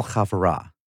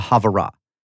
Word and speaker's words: havurah: called the Cornell Chavura, and she was intrigued.